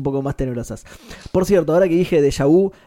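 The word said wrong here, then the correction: Jabu: es de Jabú. – De Jabú, claro, sí, sí, sí De unicornio. Sí, lo dije, lo pronuncié mal, discúlpenme.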